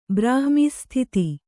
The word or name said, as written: ಬ್ರಾಹ್ಮೀsಸ್ಥಿತಿ